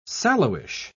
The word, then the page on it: sallowish: British English